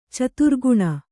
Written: ಚತುರ್ಗುಣ

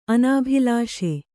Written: ಅನಾಭಿಲಾಷೆ

